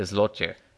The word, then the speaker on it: złoty: But it's spelled Z-L-O-T-Y, and I was fascinated by this word that if you just read it in English, you'd think, oh, zloty. But it's actually not pronounced anything like that at all.